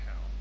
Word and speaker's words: Hal: sondern Herr. Das ist die Aussprache im Englischen, ist furchtbar. H-A-L-L ist Hall. H-A-L ist kurz. Das ist ein Kurzform für Harold. Mitunter Herr, wenn Sie, kann auch ein anderer Name sein.